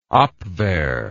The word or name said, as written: Abwehr